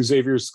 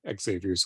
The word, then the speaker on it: Xavier's: We then cut to Xavier's school, Xavier's school. I guess it can be pronounced either way.